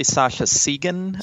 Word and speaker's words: Sascha Segan: It's actually Sasha Segan. – Okay. – But a lot of people pronounce it the other way because they think of Carl Sagan, the astronomer, and it kind of connects.